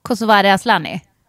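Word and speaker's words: Kosovare Asllani: Kosovare Aslani.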